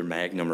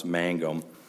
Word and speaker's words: Mangum: it's either Magnum or Mangum, I think it's pronounced Mangum, dealt with a downward departure and still said that all of the Kirby factors were ticked off.